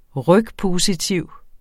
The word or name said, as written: rygpositiv